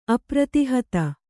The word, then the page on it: ಅಪ್ರತಿಹತೆ: apratihate